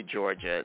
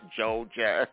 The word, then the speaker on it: Georgia: Someone said, please say Georgia, not Joja.